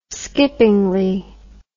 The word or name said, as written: skippingly